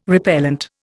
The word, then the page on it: repellent: Indian